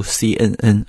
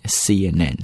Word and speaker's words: CNN: 后面呢我们看一下这则消息的来源，叫 sources have told CNN。 Sources 在这里表示的意思就是这个新闻的来源啊， have told CNN。注意在读这个 CNN 的时候不要读 CNN 啊，这也是发音 CNN， CN 有个连读， CNN 又是个连读 CNN。